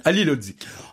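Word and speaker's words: Halilhodžić: Alors, vous sortez plusieurs footballeurs de Lille ou entraîneurs, notamment Vaïde, dont le nom, je n'oserais pas le prononcer parce que c'est très compliqué. C'est un bosniaque, que ça se termine par Zic, je crois, quelque chose comme ça. Hein. – Ali Lodzic. – Oh, c'est bien, bravo.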